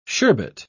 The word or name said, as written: sherbet